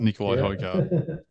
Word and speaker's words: Højgaard: And third was Nikolai Højgaard. Is that how you pronounce it? – That is exactly how you pronounce it, Nikolai Højgaard.